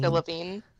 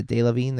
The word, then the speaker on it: Delevingne: I know you love Cara Devine. Is that how you say her name? Cara Devine? – Delevingne. – Yeah, Delevingne. There it is.